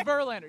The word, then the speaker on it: Verlander: It rhymes with Surlander. it's Verlander.